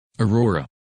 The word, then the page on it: Aurora: English